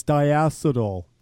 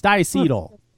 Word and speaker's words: diacetyl: Everybody's always diacetyl. It's diacetyl.